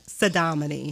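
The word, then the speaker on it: Saudamani: Yeah, Pseudonymy.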